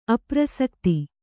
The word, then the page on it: ಅಪ್ರಸಕ್ತಿ: aprasakti